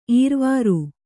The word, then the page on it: ಈರ್ವಾರು: īrvāru